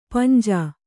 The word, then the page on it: ಪಾಂಜ: kannada